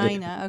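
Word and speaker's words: Marssonina: Am I pronouncing that right? Marsonia? – Marsonina or Marsonina. – Marsonina, okay.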